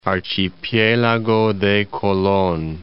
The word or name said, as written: Archipielago de Colon